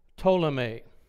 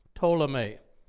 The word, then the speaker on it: Ptolemy: Now, the general who controlled Egypt, who ruled over Egypt, was named Ptolemy. Ptolemy. Silent P at the beginning of that word.